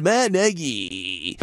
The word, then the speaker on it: Nagy: He made a point, because everybody calls him Matt Nagy because it's N-A-G-Y, but it's Matt Nagy. N-E-H-G-Y is how it's pronounced. I mean, he really went over the top to say, here's Matt Nagy.